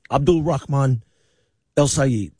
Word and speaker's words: Abdul Rahman El Sayed: He said, if you can't say his name right, to keep it his name out of your mouth. So I said it right. Abdul Rahman El Sayed.